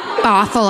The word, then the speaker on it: Bothell: But when his landlady shows up, she shows them a lengthy phone bill with a trail of calls from San Francisco to Bethel, Washington. I don't know if that's how it's pronounced. Bothell, I said. Like Bothell, Washington.